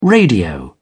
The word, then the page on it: radio: British English